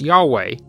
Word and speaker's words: Yahweh: It is almost certain that the name was originally pronounced Yahweh, but this pronunciation was not quite clear when the Masoretes added vowels to the Hebrew text.